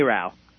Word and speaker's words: NARAL: NARAL, am I saying it right? NARAL. – NARAL, thank you.